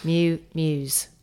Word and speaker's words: MewMews: It's called Miu Miu, but it's not Miu Miu as in Miu Miu and Prada. It's spelt M-E-W-M-E-W-S. – Mm-hmm. – Miu Miu's.